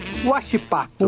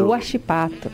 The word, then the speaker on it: Huachipato: Huachipato. – Huachipato, claro que sim.